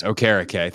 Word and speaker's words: Okereke: Am I saying that correctly? Is that right? – Yes, Okereke. Thanks, yeah, Mike Rico, for saying that properly on Sunday Night Football for me to know that that's how you say it.